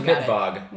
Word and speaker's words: Midtvaage: They're at Midvaggie. MIDTVAAGE. It's pronounced Midvog.